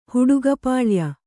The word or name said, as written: ಹುಡುಗಪಾಳ್ಯ